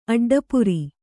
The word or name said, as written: ಅಡ್ಡಪುರಿ